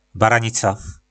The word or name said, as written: baranica